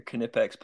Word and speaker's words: Knipex: It's German, so you pronounce the K. They're Knipex pliers.